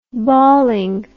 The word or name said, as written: balling